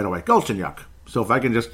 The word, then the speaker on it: Galchenyuk: As we'll finally try to say it right, Galchenyuk. Galchenyuk.